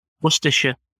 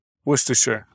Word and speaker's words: Worcestershire: Worcestershire. – Worcestershire.